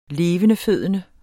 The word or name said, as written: levendefødende